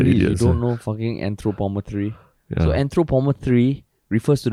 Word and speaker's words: anthropometry: Is that how do you pronounce it? Anthropometry? Yeah. – Anthropometry.